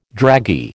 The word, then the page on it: draggy: American English